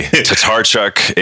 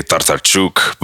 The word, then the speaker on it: Tatarchuk: Tatarchuk is Tatarchuk. How the American way to say it. My grandmother would say Tatarchuk, but I've been called tater chip, like tater chot, everything else.